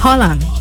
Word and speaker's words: Haaland: Holland. Cuando lleva dos A, se pronuncia O. – Holland.